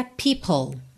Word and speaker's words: people: Você já junta o L de people em are, porque o E final de people não é pronunciado, certo? O som termina no L, people.